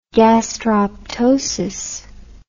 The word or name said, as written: gastroptosis